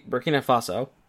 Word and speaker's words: Burkina Faso: 103, Burkina Faso.